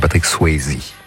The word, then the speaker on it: Patrick Swayze: pour la musique de Dirty Dancing, Patrick Swayze. Enfin, la vraie prononciation, c'est Patrick Swayze.